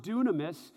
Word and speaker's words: dunamis: The word power here is the Greek word dunamis. It's spelled like dynamis, but it's pronounced dunamis,